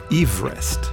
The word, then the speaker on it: Everest: Nach George Everest. Ja, das ist die korrekte Aussprache des Namensgebers.